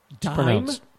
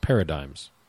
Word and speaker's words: paradigm: Dime? – It's pronounced paradigms. – D-I-G-M is dime? – Yeah. It's a silent G.